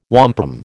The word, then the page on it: wampum: American English